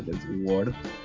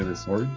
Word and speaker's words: The Sword: Eh, el grupo se llama The Sword, que yo antes le decía The Sword, pero no se dice The Sword, se dice The Sword, que es como la espada.